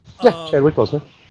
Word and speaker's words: Chadwick Boseman: The death of Chadwick Boseman. – Sure. – If I'm pronouncing his name right, yeah. – Chadwick Boseman, is that what you said? You cut out a little bit. Yeah, Chadwick Boseman.